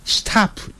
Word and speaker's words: stop: 以后你要看了另外一个字叫 STOP，停下来就不念成 stop， stop 就不对了， stop stop。